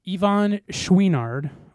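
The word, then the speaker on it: Yvon Chouinard: I think that's how you pronounce his... That is how you pronounce his last name. I'm telling you right now. Yeah, I know that for sure. Schwinard. I think it's Ivan. It's Y-V-O-N. Yeah. Ivan Schwinard.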